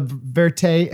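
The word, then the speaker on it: vérité: and you wanted to have this like cinema verite.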